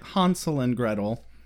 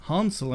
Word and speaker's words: Hansel and Gretel: Or as they made a very clear point of pronouncing it, Hansel and Gretel. Even Xander was like, Hansel and Gretel? Why, yes, Xander, Hansel and Gretel. Not a single person at any point said Hansel, like the Midwestern tool bags we are.